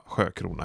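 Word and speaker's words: Siökrona: Jag tror man uttalar det sjö, men stavningen är S-I-Ö och sen krona. Ja, men vi bestämmer det nu att hon uttalas Vera Sjökrona helt enkelt.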